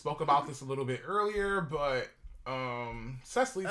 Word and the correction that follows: Cicely: Tyson, Cicely Tyson specifically, that's how you pronounce her name, Cicely.